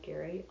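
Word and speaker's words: Gerry: You can find stories about travel by Gary on All Things Cruise. It's G-E-R-R-Y, but it is pronounced Gary Barker.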